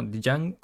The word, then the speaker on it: Django: como, Django, como pero la D se pronuncia, no es moda, de Mike102.